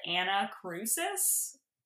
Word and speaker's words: Annacrusis: So we are called Anna Crucis, but it's spelled A-N-N-A-C-R-U-S-I-S.